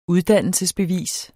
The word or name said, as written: uddannelsesbevis